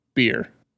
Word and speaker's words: Bier: Beer. – In German? – Yeah. It's just B-I-E-R. – Oh. I'd be fine over there then. Beer. – And it's cheaper than water. – Beer, bitte. – Yes. – Bitte is please. – Bitte? – Bitte. B-I-T-T-E.